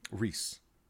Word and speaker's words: Rhys: But Rhys. Is it? Okay. – It is.